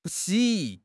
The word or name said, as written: 不惜